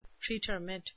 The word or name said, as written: pretermit